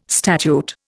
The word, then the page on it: statute: Indian